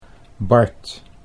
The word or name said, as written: Bart